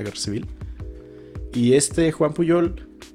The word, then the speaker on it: Pujol: Eh, existe una historia, hay, existe un español que se llamaba Juan, Juan Puyol, Juan Pujol se escribe,